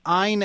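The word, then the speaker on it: Ayn: Ein as in Schwein.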